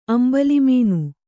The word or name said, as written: ಅಂಬಲಿಮೀನು